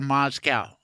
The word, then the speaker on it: Moscow: I assume you lived in Moscow, and is that the right way to say it, Moscow or Moscow? – Either way, that's the English pronunciation. Russian is Moskva.